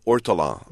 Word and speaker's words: ortolan: It's called ortolan.